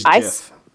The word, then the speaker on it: GIF: It is GIF. – I say GIF. My sister always says, choosy tumblers, choose GIF. – The creator of the GIF said that it is pronounced GIF. So therefore, it is GIF.